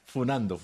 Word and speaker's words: funando: Y ahora no se dice cascando, ahora se dice funeando. – Pero eso es youtuber, un mensaje de 48 años, por favor. – Sí, funando, me aclaran.